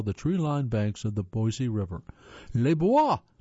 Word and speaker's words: Boise: Boise, pronounced Boise by locals, not Boise by locals,